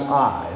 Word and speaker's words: Ai: Just like Joshua felt after the children of Israel lost the battle at the city of, spelled Ai, just pronounced I, the city of Ai.